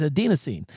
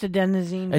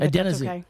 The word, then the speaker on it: adenosine: And it depends on a substance known as adenosine. As we age, the brain continues to accumulate fatigue, but responds less effectively to the signal. – It's pronounced adenosine. – Adenosine.